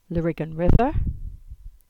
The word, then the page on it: Lariggan River: English